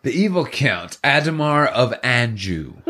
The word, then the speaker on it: Adhemar: I say Adhemar. – Adhemar? – That's how I've been saying it. – That's how you've been saying it. Because it's A-D-H.